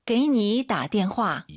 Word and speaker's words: 给你打电话: 다는 올라가면 안 돼요. 다는 뒤에 사성이 있으니까 반삼성이죠. 게이니 다. 게이니다. 이 연습을 하셔야 됩니다. 선생님, 게이니다부터 한번 다시 해주세요. – 给你打电话. – 이게 바로 반삼성입니다.